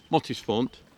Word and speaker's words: Mottisfont: And of course, gardens like Mottisfont Is that right?